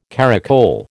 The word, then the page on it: caracole: American English